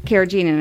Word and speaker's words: carrageenan: Carrageenan, I think, yeah.